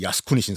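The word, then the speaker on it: 야스쿠니 (靖国): 정국이 일본식 발음으로 야스쿠니 신사가.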